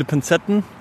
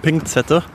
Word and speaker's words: Pinzetten: Oder bei mir auf der Arbeit gibt es viele Pinzetten und äh, manche der Chefs sagen Pinkzette. Macht mich auch wahnsinnig.